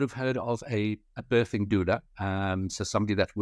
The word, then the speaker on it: doula: So, when we're doing our sort of background on you, we saw you used the term business doula.